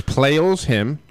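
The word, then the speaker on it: Pleyel's Hymn: Yeah, it's Pallel's Hymn. I believe you pronounced it correctly. It's Pallel's, as in the possessive, hymn.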